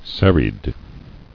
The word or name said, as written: Serried